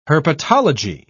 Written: herpetology

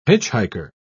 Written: hitchhiker

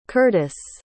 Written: curtice

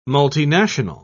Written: multinational

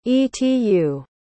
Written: etu

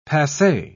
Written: passe

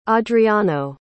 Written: adriano

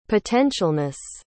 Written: potentialness

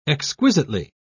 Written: exquisitely

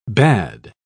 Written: bade

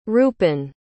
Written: rupin

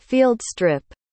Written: fieldstrip

